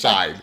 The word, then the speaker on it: Shein: I'd be like, oh my God, my Shein $10 purse. – Shine. You know, it's pronounced Shine. It's Shein. It's Shine. I looked it up. The way you say it in English is Shine.